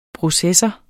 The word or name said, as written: processor